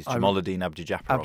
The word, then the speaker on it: Jamaluddin Abdujaparov: It's got to be the Azerbaijani, or is he Kazakhstani sprint specialist from the early 90s, Jamaluddin Abdujaparov. – Abdujaparov, what a great name that is. – I remember him well, and I'm highly impressed with your powers of recall and pronunciation.